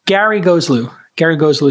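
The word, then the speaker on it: Gharegozlou: Gary Goeslew is how you pronounce his last name.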